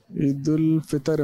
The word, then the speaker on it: عید الفطر: ایک تلفظ درست کرنے کی بھی ہم کوشش کریں گے. عموماً عید کے دن ایک لفظ کہا جاتا ہے عید الفطر یا عید الفطر, صحیح لفظ کیا ہے؟ – عید الفطر عمران دے.